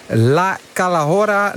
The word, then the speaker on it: La Calahorra: Het is een loodzware bergetappe van bijna 187 kilometer van, moet ik het in mijn beste Spaans zeggen, La Calahorra na Collado del Alguacil. Toch, wielercommentator Andries Lamin? – Ja, toch. Als in dat ik eventjes die uitspraak beoordeel. Nou, helemaal goed, helemaal goed.